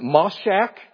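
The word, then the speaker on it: mashach: This word anointing, meshach, or no, I'm sorry, I mispronounced that, moshach.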